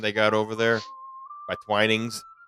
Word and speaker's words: Twinings: I call it Twinings. No, you got it right. Twinings.